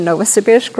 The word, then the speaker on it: Novosibirsk: During the past 12 years, we served a mission in Novosibirsk, Russia. You said it correctly.